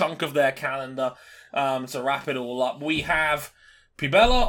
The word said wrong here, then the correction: Puebla: on June 19th and the 20th. – It's Puebla.